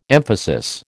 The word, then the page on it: emphasis: American English